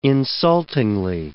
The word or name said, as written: insultingly